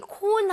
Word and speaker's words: cone: 근데 우리가 말하는 그 콘 모양을 얘기를 할 때는 C-O-N-E, R 사운드가 아예 없이 깔끔하게 네.